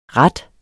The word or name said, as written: RATTENE